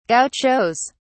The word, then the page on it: gauchos: British English